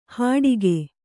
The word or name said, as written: ಹಡಿಕೆ